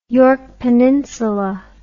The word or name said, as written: yorke peninsula